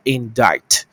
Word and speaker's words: indict: 所以各位同学，你会讲起诉的英文哦。起诉其实就用控告，所以起诉的话两个讲法，第一个叫做 indict。 C 不发音。第二个 prosecute，prosecute 这个字叫起诉，所以同学，那个如果我用人来讲，OK 哈，人知道吗？